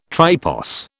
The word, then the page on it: tripos: British English